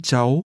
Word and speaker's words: cháu: Chị được mấy cháu? Chị được mấy cháu? – First, the part that means children. – Hãy nghe và nhắc lại. Cháu. Cháu. Cháu. – This is pronounced with a rising tone.